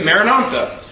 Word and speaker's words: Maranatha: We recommend the prayer phrase, Maranatha. Recite it as four syllables of equal length.